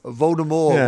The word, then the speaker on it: Voldemort: I thought it was Voldemort. – What? – Voldemort. Yeah. – Silent T. – Apparently. Well, at least in this play universe, Voldemort with a silent T.